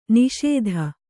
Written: ನಾಶ್ತಾ

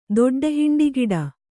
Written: ದೊಡ್ಡಹಿಂಡಿಗಿಡ

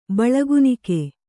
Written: ಬಳಗುನಿಕೆ

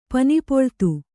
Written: ಪನಿಪೊಳ್ತು

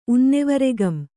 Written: ಉನ್ನೆವರೆಗಂ

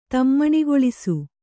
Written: ತಮ್ಮಣಿಗೊಳಿಸು

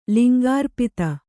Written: ಲಿಂಗಾರ್ಪಿತ

